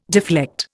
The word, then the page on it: deflect: Indian